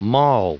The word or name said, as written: maul